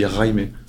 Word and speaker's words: Jaime: Et je, suis venu le voir la première fois en lui disant Jaime, euh, ça va Il m'a dit non, Jaime, tu vois. – Ah, ok. – Donc tu prononces le R et tu dis Raime.